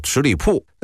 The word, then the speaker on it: 十里堡: 北京有个地名叫十里堡啊，这名字这个十里堡的这个堡字就碉堡的堡啊，但是呢北京人读音啊都叫十里铺。为什么十里堡要念十里铺呢？